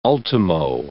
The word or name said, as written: ultimo